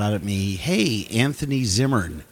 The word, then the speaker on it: Zimmern: the, the funniest kind of because uh, it's not Zimmerman. – No, it's and, Zimmern. and it's Zimmern, and it's hard to say.